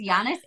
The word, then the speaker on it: Giannis: That's close. It's Giannis Antetokounmpo.